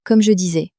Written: comme je disais

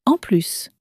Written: en plus

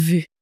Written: vu

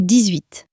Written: dix huit

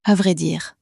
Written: a vrai dire

